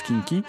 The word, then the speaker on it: quinqui: Ça s'écrit kinky, hein, Q-U-I-N, Q-U-I, mais ça se prononce kinky.